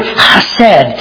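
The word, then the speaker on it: hesed (חֶסֶד): In the Hebrew, it's hesed. And it's spelled a couple different ways. When you transliterate it in English, it's either C-H-E-S-S-E-D or just H-E-S-E-D, but it's the word chesed.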